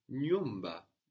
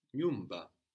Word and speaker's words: nyumba: The word for house is nyumba. Nyumba. – Nyumba. – NYUMBA. So again, we have this NY sound starting a syllable. Nyumba. Like in nywa. But here it's slightly easier. Nyumba.